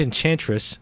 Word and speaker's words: Enchantress: and Enchantress will be absent. – You said her name wrong. Enchantress.